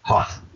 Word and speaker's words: Hough: Is Simon's last name pronounced Hough? – Yes, it is. – Okay, we were wondering that before. So, okay, Simon Hough, got it. – Hough, yep.